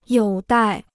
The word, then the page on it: 有待: yǒu dài